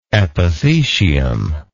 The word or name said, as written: epithecial